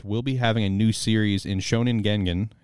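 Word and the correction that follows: Gangan: is that how you pronounce it? Gengen. Gan Gan. – Yeah. Gan sure. Gan.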